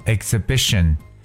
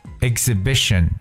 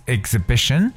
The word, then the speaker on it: exhibition: e x h 当中呢，这个 h 也是不发音。譬如说，我们知道展览这个词叫做 exhibition。 exhibition，而不读 exhibition，it's exhibition。